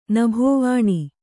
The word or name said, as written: ನಭೋವಾಣಿ